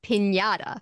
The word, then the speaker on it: piñata: And we all know that it's pronounced piñata.